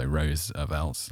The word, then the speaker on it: Roosevelt: We did the Roosevelt one, which every time I say Roosevelt, I've got to think about the pronunciation. So Roosevelt, like he's a rose, is always how I imagine him.